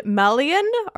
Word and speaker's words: Melian: Chapter four is of Thingol and Melian. Are those correct pronunciations? – Correct. You even did Melian right. I've been mispronouncing Melian's name for years. I keep wanting to say Melian, and that's incorrect. It's Melian.